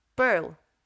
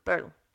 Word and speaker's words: Purl: Pearl, no sé cómo pronunciarlo así sin sonar muy rimbombante en inglés, pero es P-U-R-L. Pearl. Pearl, sí, eso.